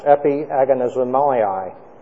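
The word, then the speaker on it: epagonizomai: The word here is a difficult one to pronounce. Epi agonizomai, being composed of two words.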